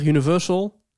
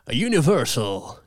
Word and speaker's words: Universal: Zou je Universal iets minder op zijn Hollands willen uitspreken? Universal, zeg jij. – Volgens hem. Als een soort Ursel de Geer of zo. Ja. Universal. Dus ik zeg, ik zeg Universal, maar het moet Universal.